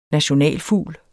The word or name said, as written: nationalfugl